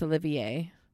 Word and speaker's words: Olivier: Oliver Martinez. He's the heartthrob. – Olivier. Is it Olivier? – Right here. Am I saying his name wrong? – Yeah, it's Olivier.